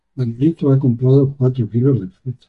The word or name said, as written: comprado